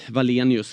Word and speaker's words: Valenius: Leo där, Salin, Valenius, va? Är det rätt uttal? – Ja.